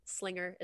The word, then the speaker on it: Slinger: Michaela is spelled like Michael with an A, and then Slinger is like singer, but with an L.